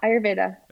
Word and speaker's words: Ayurveda: Yeah, Ayurveda.